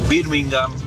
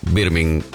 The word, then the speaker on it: Birmingham: Todos los equipos que terminan con ham, uh-huh, H-A-M, bueno, la H es muda. Ejemplo, Tottenham, Birmingham. Creo que la única excepción es West Ham, pero justamente porque se escribe separado.